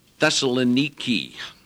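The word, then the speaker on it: Thessaloniki (Θεσσαλονίκη): So after they passed through Amphilus and Apollonia, they came to Thessalonica, which in the Greek is Thessaloniki. Very interesting pronunciation.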